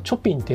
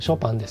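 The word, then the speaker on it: Chopin: いな今日のこの感じだとね、えー、なんかこんなダラダラこればこんなあガゼボガゼボいきますか雨音はショパンのなんだっけ調べでしたっけ「アイライクショパン」これをチョピンって読んじゃうっていう人がいますけれどもショパンですよ。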